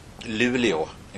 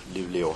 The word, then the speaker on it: Luleå: The town is Luleå. It's spelled L-U-L-E-A with a ring above it, Luleå.